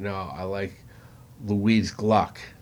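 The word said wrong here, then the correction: Glück: I think she's a wonderful poet. Or Glick. I think it's pronounced Glick. G-L-U with an umlaut, C-K.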